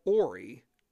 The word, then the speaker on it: Horry: Now, his last name is actually spelled H-O-R-R-Y, and to us that would be pronounced as Horry, but believe it or not, it is spelled Ory.